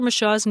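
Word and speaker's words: Micheaux: Mr. England wrote us, and he says, "I often hear Oscar Mashaw's name pronounced up to four ways." In a 1981 documentary that included interviews with actors Bee Freeman and Lorenzo Tucker, both pronounced the name Mashaw sometimes, but more often Mashaw.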